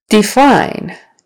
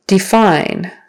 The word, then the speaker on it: define: Define, define, second syllable stress.